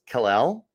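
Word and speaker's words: Kel'el: I believe it's pronounced Kal-El, like Superman.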